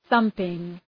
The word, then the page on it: thumping: ‘ɵʌmpıŋ